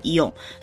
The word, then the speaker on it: イオン: 如果用现代的英文来发音的话呢，就会叫做イオン。所以日文通常就会把这个品牌叫做イオン。